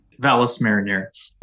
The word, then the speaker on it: Valles Marineris: Whereabout on Mars is the Valley de Miranius? Am I pronouncing that halfway correctly? – Valles Marineris.